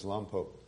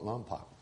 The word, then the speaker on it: Lompoc: You can always tell when somebody isn't from there because they'll call it Lompoc. Because it's spelled L-O-M-P-O-C, and so they pronounce it Lompoc, but it's Lompo, right?